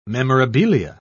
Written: memorabilia

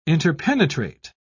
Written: interpenetrate

